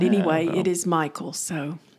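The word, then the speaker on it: Mikael: it's M-I-K-A-E-L. – Right. – But it's Michael.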